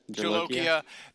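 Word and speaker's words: Bhut jolokia: They have Bucholiquia. Is that right? Did I say it right? – Bucholiquia. – Jalokia.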